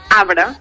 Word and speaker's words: arbre: La Mire és una paraula que s'escriu amb R, però genuïnament aquesta R no sona. És a dir, com s'hauria de dir, com ella ho diu, arbre, però cada vegada més, per la influència de la grafia, sentim gent que diu arbre.